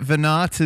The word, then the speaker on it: Veinotte: So Brett Veinotte is back here on the podcast, and I said it correctly that time.